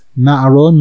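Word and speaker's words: na'aro (נערה): In other words, it's pronounced na'aro, nun ayin resh hey, as if it had a hey at the end, but it's written without the hey, as if it were the word na'ar.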